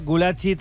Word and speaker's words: Gulácsi: Ese Peter Gulácsi, ens ha dit Ros Negroles que així es pronuncia Gulácsi, 36 anys, procedent del Leipzig, que acaba de firmar per el Villarreal i que acaba de ser presentat.